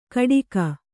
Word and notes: ಕಟಿಗ: Kannada

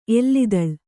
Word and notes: ಎಲ್ಲಿದಳ್: kannada

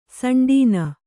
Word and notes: ಸಂಡೀನ: kannada

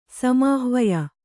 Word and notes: ಸಮಾಹ್ವಯ: Kannada

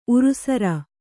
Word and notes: ಉರುಸರ: urusara

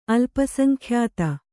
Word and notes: ಅಲ್ಪಸಂಖ್ಯಾತ: Kannada

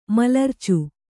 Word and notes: ಮಲರ್ಚು: malarcu; kannada

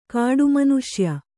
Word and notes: ಕಟ್ಟಾಮನುಷ್ಯ: kaṭṭāmanuṣya

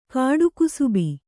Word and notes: ಕಾಡುಕುಸುಬಿ: kāḍu kusubi; Kannada

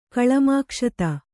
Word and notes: ಕಳಮಾಕ್ಷತ: Kannada

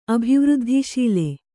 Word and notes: ಅಭಿವೃದ್ಧಿಶೀಲೆ: abhivřddhiśile

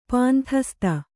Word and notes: ಪಾಂಥಸ್ತ: Kannada

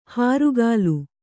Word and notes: ಹಾರುಗಾಲು: hārugālu

